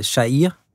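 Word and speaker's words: Jair: Og jeg ved slet ikke, om jeg udtalte Share Bolsonaros navn rigtigt, eller oversatte helt korrekt, hvad det var, Flavio her fik sagt i sin jiu-jitsu-dragt. – Jo, det tror jeg. Altså, Shair. Altså, Shair.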